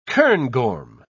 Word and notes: cairngorm: British English

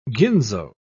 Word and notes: ginzo: British English

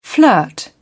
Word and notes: flirt: British English

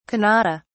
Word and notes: kannada: British English

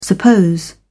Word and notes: suppose: British English

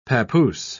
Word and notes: pappoose: British English

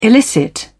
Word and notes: illicit: British English

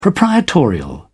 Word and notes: proprietorial: British English

